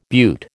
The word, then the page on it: butte: American English